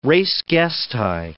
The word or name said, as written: res gestae